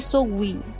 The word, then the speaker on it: Samhain: Eu não sei, eu não sei a pronúncia, mas a, me falaram que a pronúncia é Samhain.